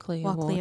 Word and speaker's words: Walkley: Did I say it right? Walkley? – Walkley Award.